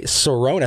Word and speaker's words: Cerrone: And of course, we have the big fight this weekend with Conor McGregor and Cowboy Cerrone. I think that's how you pronounce his last Cerrone. – Name. Cerrone. Okay, yeah.